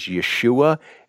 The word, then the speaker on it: Yeshua: Jesus, the Hebrew pronunciation is Yeshua, is the same name as Joshua.